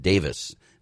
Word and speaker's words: Davies: And over across the pond, well, they call it, his last name is pronounced Davis. D-A-V-I-E-S is Davis over there. Over here, we call him Ray Davies. So I'm going to call him by the proper pronunciation, Ray Davis.